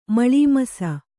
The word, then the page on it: ಮಳೀಮಸ: Kannada